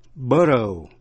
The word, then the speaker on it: borough: Borough, B-O-R-O-U-G-H, borough, là quận hành chính tự trị ở New York City. New York City has 5 boroughs. Nhớ, G-H câm, không phát âm. Chữ nữa là through, T-H-R-O-U-G-H, là qua.